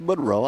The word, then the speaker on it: Monroe: It's pronounced Monroe.